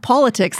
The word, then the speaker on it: Paulatics: And follow me at Paulatics. That's PAULATICS. – That is correct. And that's how you say it. Do you get it? She wrote about politics. She's in politics. Politics. Okay, anyways.